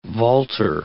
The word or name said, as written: vaulter